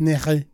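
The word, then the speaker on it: Neige: In France, it's Nicky.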